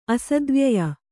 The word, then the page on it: ಅಸದ್ವ್ಯಯ: Kannada